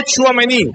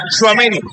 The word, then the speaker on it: Tchouaméni: No Tchouaméni, Tchouaméni, sin el acento en la última letra.